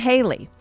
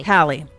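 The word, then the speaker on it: Halle: Musical performances will include Lizzo, Camila Cabello, Chloe and Haley. Did I say that right? – Hallie.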